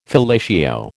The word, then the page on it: fellatio: American English